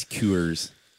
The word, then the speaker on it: Coors: I've also brought the usual Bobby Coldrick's chaser of a 24-ounce Coors Light that for some reason... – That's not how it's pronounced. – It's Coors.